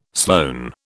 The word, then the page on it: Sloane: British English